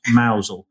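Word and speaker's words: Mousehole: So after that, after St. Michael's Mile, we went off to, our intention was to go and park at Mousel, but that didn't kind of work out, did it? – It's, it's Mousehold, pronounced Mousel.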